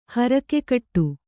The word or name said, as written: ಹರಕೆಕಟ್ಟು